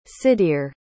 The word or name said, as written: Sydir